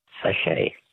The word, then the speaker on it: sachet: Sachet. – A sachet. S-A-C-H-E-T.